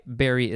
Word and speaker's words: bury: I should specify, bury is spelled B-U-R-Y. It sounds like the berry that you eat.